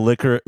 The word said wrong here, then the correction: licorice: licorice black? Sorry, I have to say it correctly.